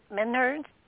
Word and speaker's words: menhirs: with the passage tombs and the menhirs. I always say it right. – Menhirs, yes, thank you.